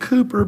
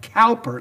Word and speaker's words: Cowper: Many of you know of William Cooper, I'm saying Cooper, but his name is spelled C-O-W-P-E-R, like Cowper. It looks like Cowper, but I have been told you are to pronounce it Cooper.